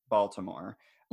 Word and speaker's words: Baltimore: Like, who decided on, I think that Baltimore in the 1970s, I'm from Maryland, but not anywhere near Baltimore. Mm-hmm. And you can tell that because I'm pronouncing the T in Baltimore.